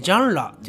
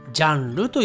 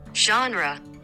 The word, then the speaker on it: genre: えー、ジャンラっていうのかな。はい。発音が難しいので、日本人は大体ジャンルと言っていますね。